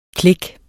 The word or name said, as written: click